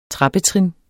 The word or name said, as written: trappetrin